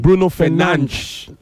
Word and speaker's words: Bruno Fernandes: When you mispronounce his name, that's a problem. Bruno Fernandes. Please.